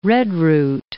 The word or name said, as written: redroot